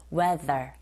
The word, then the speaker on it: weather: 발음할 때 그냥 우리말처럼 디귿자 발음하면서 weather가 아니라 TH 발음 자체를 윗니 아랫니 사이에서 혀를 살짝만 긁어주세요. weather, weather.